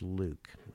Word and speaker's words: LIWC: And the computer program was called, we later called it Linguistic Inquiry and Word Count, LIWC, which we pronounced Luke. And I know it doesn't sound look like Luke, but it is.